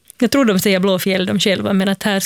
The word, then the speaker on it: Blåfield: Blå, det som folk brukar tendera att uttala Blåfield, alltså blå, alltså en F-I-E-L-D, för yeah. att det är en gammal stavning av ordet fjäll. Så det namnet är Blåfjäll.